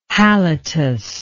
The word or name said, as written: halitus